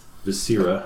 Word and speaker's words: viscera: The second form, the line which says vascular and respiratory organs and abdominal viscera, I believe it's pronounced, I could be wrong.